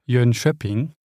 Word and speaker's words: Jönköping: In äh, Jönköping.